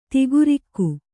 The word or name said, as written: ದೇಖರಖಿ